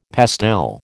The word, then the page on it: pastel: American English